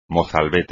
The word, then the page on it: mozalbete: espagnol